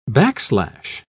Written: backslash

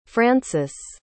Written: francis

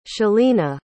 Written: chalina

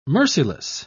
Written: merciless

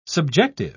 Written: subjective

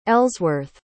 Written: Ellsworth

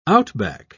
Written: outback

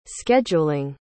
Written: scheduling

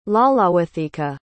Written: Lalawethika

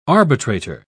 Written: arbitrator

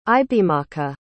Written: ibeamaka

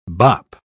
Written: bop

